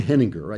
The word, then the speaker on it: Henninger: So Dan Henninger, I think he pronounces it with a hard G,